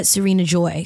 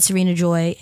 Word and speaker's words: Sarina-Joi: Yeah, everything is at Sarina Joy. So my name is spelled S-A-R-I-N-A-J-O-I. I'm sure it'll be on the title of this video, but yeah. – Hell so everything's at Sarina Joy.